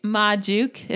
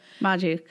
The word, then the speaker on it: Majuk: So it's Majuk. If anyone is looking at my name having no idea, Majuk. Majuk. So ma as in ma, like come on ma, and then juke jukebox ma juke.